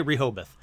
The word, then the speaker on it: Rehoboth: Let's find out together how to say this. – Rehoboth. – Rehoboth. – Rehoboth. – Rehoboth. Alrighty, so we're going to say Rehoboth.